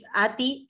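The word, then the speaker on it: Aty: Sí, bien, me pueden ubicar en Facebook como Sandra Beatriz Ati, que es A-T-Y y mi apellido se pronuncia de Bef, pero se escribe D-E separado B larga O-E-U-F.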